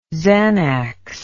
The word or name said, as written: xanax